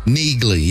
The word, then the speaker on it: Neagley: Neegly? – Neegly, yes. – How do you spell that? – N-E-A-G-L-E-Y.